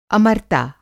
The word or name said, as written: अमरता